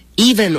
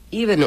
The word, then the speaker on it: Even: And Evan Ormistead. Even Ormistead. – Even. – Even Ormistead.